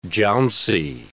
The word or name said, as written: jouncy